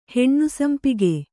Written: ಹೆಣ್ಣುಸಂಪಿಗೆ

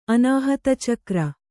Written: ಅನಾಹತಚಕ್ರ

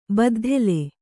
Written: ಬುದ್ದಲಿ

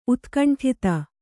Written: ಉತ್ಕಂಠತೆ

